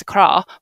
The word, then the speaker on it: Accra: But you pronounced it Accra. You said Accra. I was like, it's Accra. But okay, yeah.